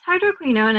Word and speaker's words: hydroquinone: Yes, hydroquinone, and you've got the pronunciation perfect.